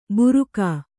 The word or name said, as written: ಬುರುಕ